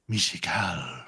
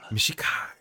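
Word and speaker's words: Mishakal: It's pronounced Mishukel. – Mishukel.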